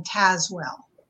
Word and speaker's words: Tazewell: It has a lot of silent E's in it. So it's actually pronounced Anne Tazewell.